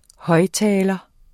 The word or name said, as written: højttaler